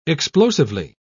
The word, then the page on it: explosively: British English